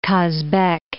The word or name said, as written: Kazbek